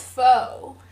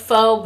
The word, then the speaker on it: pho: I thought it was pho. – Well, it's spelled like pho, but it's pronounced pho.